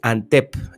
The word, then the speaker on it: Antep: Resulta que Antep se escribe Antep en turco y se pronuncia más o menos igual.